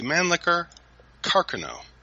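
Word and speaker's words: Mannlicher-Carcano: is actually pronounced the Mannlicher Carcano.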